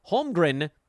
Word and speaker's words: Holmgren: Holmgren, the M is in the middle. The N is at the end.